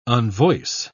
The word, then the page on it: unvoice: British English